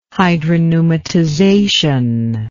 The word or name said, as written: hydropneumatization